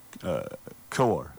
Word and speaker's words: Kowar: You got Brady Singer and then Kukar. Is that how you pronounce it? – Kowar. Yeah, Kowar. – Jackson Kowar. – Yeah, Kowar. Yeah.